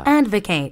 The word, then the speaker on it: advocate: Advocate. – Advocate. – Advocate. Advocate. – 명사로 쓸 때는 advocate 이렇게 발음해야 돼요.